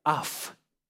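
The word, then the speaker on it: аф (אף): Ну, во-первых, звучит оно так. Слово «гнев» – это «аф». «Аф». «А» и «а» F, если по-русски произносить так, транслитерация.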